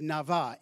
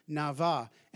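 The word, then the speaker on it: nava (נבא): nava, N-A-B-A is what it looks like, but pronounced nava, and it literally means to bubble up.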